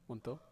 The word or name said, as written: wonto